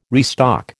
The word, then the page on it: restock: American English